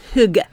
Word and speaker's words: Hygge: I've been calling it Higgy, but that... – No. Hygge.